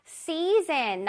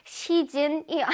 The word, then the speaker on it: season: Season 나왔어요. Season. 시즌이 아니고 season.